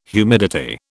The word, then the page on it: humidity: British English